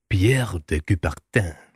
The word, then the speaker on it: Pierre de Coubertin: Så etter mye om og men, så måtte Pierre de Coubertin, jeg vet ikke helt om jeg har uttalt det riktig. – Helt korrekt.